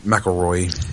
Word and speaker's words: McIlroy: I can't talk about Rory McIlroy. – McIlroy, yeah, make sure you pronounce that correctly. – McIlroy? This is McIlroy? – Kilroy, yeah.